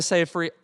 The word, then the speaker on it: Seyfried: Amanda Seyfried, rhymes with rye bread, in Mank.